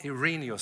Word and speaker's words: Irenaeus: And what Irenaeus accurately said was this Irene, Irene, I always get his name wrong. What Irenaeus said is, For the glory of God, is a living human being, and the life of a human consists in beholding God.